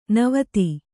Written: ನಿವಾತ

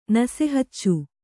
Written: ನಸೆಹಚ್ಚು

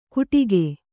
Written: ಗುಟಿಕೆ

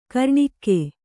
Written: ಘೂರ್ಣಕ

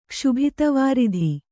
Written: ಕ್ಷುಭಿತವಾರಿಧಿ

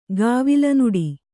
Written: ಗಾವಿಲನುಡಿ

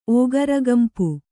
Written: ಓಗರಗಂಪು

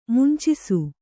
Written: ಮುಂಚಿಸು

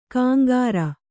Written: ಕಾಂಗಾರ

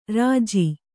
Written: ಋಜು

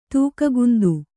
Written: ತೂಕಗುಂದು

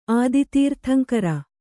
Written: ಆದಿತೀರ್ಥಂಕರ

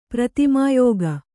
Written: ಪ್ರತಿಮಾಯೋಗಿ

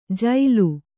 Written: ಜೈಲು

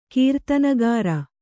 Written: ಕೀರ್ತನಗಾರ

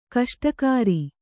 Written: ಗೋಷ್ಠಾಗಾರ

